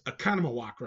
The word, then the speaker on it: Oconomowoc: and it's Economowoc, right? Did I say it right? – You did. That's correct.